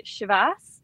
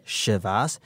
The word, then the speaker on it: Chivas: As long as we don't head down the Chivas, I guess we're all okay, right? – Yeah, I think if we go with the SH pronunciation to start with, we're on to a good one.